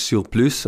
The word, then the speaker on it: SIRPLUS: Der Name, also äh, wenn man es mit U schreiben würde, würde es ja Surplus, genauso ausgesprochen, äh, oder auch auf Französisch Surplus, äh, das heißt Überschuss.